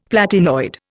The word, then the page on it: platinoid: Indian